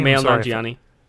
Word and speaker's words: Kumail Nanjiani: Kumail Nanjiani, is that how you say his name? Say his name? Kumail Nanjiani.